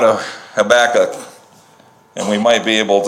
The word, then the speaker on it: Habakkuk: Habakkuk, Habakkuk. Emphasize the last syllable. Habakkuk.